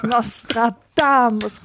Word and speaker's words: Nostradamus: Die dritte Silbe betont klatschen, bitte. Nochmal. – Nostradamus, genau.